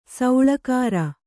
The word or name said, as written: ಸೆಳ್ಳುಗುರು